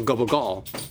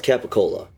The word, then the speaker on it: capicola: So Italian, like, growing up, we used to eat this meat called gubble gall. But in proper, like, sort of shishi, rich, fucking Roman Italian... In like big city Italian, it's called. – It's spelled capicola. – Capicola. – But like I've never called. – No, we call it gubble gall.